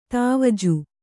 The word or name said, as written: ದ್ವಿಜ